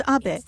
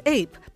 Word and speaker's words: Abe: My name is Abe, but in the United States, some people mistakenly pronounce my name as Abe, but that is not bad, because even in Japan, everybody knows the name of that great president.